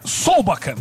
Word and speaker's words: Solbakken: É o Solbakken, ou Solbakken, não me lembro a pronúncia correta dele, acho que é Solbakken, né? É isso? – É, Solbakken.